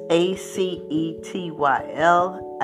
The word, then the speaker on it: acetyl: It's A C E T Y L, acetyl.